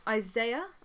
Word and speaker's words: Isaiah: And then, obviously, they go down to see Isaiah. I think that's how you say it.